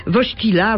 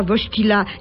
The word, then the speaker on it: Wojtyła: Dziesiątki dziennikarzy z kamerami oblężyli nas w nadziei zdobycia dodatkowych informacji o tym nowym papieżu, fotografując dyplom ze wszystkich stron, ucząc się wymawiać nazwisko Wojtyła. Ach, jakie to trudne. Wojtyła, Wojtyła. Nie, Wojtyła.